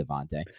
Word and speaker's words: Devonta: They found Devonta Freeman off the Devonta Freeman off the You said it correctly finally. No, good job. No, welcome to the dark side. – Just go find that interview where he confirms that it's Devonta.